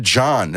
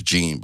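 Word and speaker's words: Jean: So Botham John. That's how they say it. – Yeah. – I thought it was Gene, but it's John.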